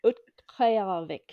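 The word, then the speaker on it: Utqiaġvik: That's Utqayarvik. So the K is the one at the back of your throat. Utqayarvik.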